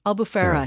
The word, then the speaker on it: Albufeira: Is it pronounced Albufeira? – Albufeira, yeah. Absolutely.